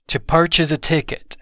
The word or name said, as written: to purchase a ticket